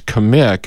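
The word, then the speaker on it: Kmiec: So it's Kamik, and it's K-M-I-E-C. – Right. – I would never guess Kamik. – Easy to pronounce, but spelled funny.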